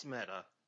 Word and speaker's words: Meta: Is it pronounced meta? – No, it's meta.